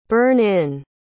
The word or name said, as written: burn-in